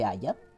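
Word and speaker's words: haya: El caso es que no se dice aiga, se dice Aya Pero la RAE acepta la palabra aiga.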